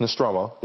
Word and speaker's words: Nostromo: Yes, Nostromo.